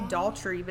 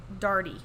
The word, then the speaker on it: Daughtry: I want to say Daughtry, but that's not how you say it. – It's Darty? Like a daughter? – Darty. I believe that's how you say her last name.